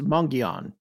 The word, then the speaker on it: Mogollon: this is spelled ah. – M O G O L L O N, but everyone says Mungion. Some people put a little N in there at the end of the first syllable, some don't. There's a gi, even though it's go.